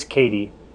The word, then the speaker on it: Kadi: And I think I mispronounced your name, so it is Kadi, not Kadi. – Yeah, that's right. Yep, Kadi long A, yep.